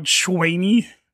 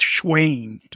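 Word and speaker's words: Shayne: I get called Schwaney. – Yeah. No, I believe it. – Schwaine. – I believe it. – It's, like Wayne, right? – Yeah. – Like Wayne, but it's Schwaine.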